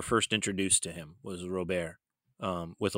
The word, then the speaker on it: Robert: So, well, sky's the limit for Luis Robert, and that is how he says it, he pronounces it Robert, even though I think if he's completely honest, you roll that R, sounds a little bit different, more like Robert. You know, but it's just easier to pronounce it out. – My point being, it's not Robert. Everybody for some reason seems to want to say Robert, but that's wrong.